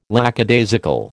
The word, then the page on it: lackadaisical: American English